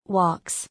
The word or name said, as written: Walks